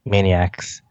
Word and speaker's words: Maniacs: Maniacs. Like the Animaniacs.